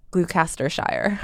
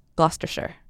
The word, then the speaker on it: Gloucestershire: It is not called Gloucestershire. – Well, I knew that. – It is called Gloucestershire.